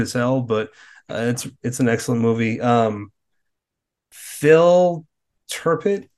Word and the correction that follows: Tippett: Mad God. Is that it? Is that how you say his name? Phil Tippett. That's it.